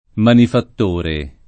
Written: manifattore

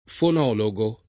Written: fonologo

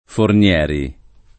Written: Fornieri

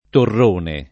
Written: torrone

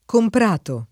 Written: comprato

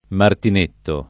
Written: martinetto

